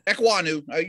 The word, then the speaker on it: Ekwonu: Ekwonu, you said it right.